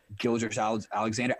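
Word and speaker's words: Gilgeous-Alexander: You know, he's got those rookies that start, I think, Gilgeous Alexander. – There you go. You said it right.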